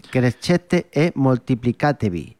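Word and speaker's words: Crescete e moltiplicatevi: Seguimos ahora con un Crescete, a ver si lo digo bien. Crescete e Multiplicatevi. – Muy bien.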